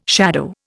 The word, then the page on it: shadow: Indian